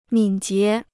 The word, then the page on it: 敏捷: mǐn jié